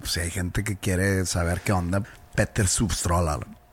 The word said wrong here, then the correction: Stordalen: Petter es P-E-T-T-E-R. Stordalen es S-T-O-R-D-A-L-E-N. Stordalen. Stordalen, sí.